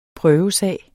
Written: prøvesag

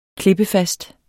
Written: klippefast

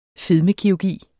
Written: fedmekirurgi

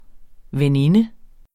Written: veninde

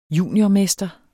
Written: juniormester

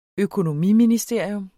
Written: økonomiministerium

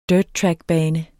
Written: dirttrackbane

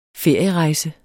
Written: ferierejse

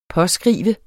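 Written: påskrive

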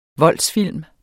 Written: voldsfilm

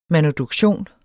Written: manuduktion

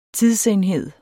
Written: tidsenhed

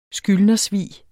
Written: skyldnersvig

